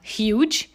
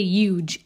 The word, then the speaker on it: huge: Okay, so another pet peeve of mine is when people think the letter H is silent in the word huge. And they say huge. And I see that, like, way too often, and it's not okay.